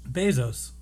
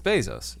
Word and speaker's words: Bezos: How do you pronounce his name? You say Bezos? – Bezos. – It's Bezos. 100%.